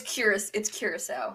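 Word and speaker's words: Curaçao: You say it's curacao. That's how you say it, not curacao, right? – It's curacao. Yeah.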